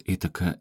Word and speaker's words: Итака: Тебя зовут Итака Экспосита. – Итака в испанском языке ударение на первый слог.